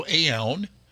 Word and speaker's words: Aoun: Joseph Aoun, I believe is how you pronounce his name. A-O-U-N. If that was an N-O-U-N, that would be noun. So Aoun, he